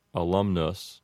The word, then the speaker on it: alumnus: pronounces each of those U's with a short U sound that sounds like uh. So that word is going to be pronounced alumnus, that is, if you're using an Americanized pronunciation.